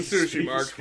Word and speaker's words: Tsushima: But seriously, how do you pronounce it? Ghost of what? – Ghost of Tsushima.